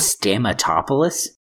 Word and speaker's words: Stamatopoulos: Dino Stamta. – It's been a while since we've had mispronounces a name on the show as a segment. – Stamatopoulos? – Oh, struggling with those Greek names.